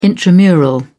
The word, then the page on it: intramural: British English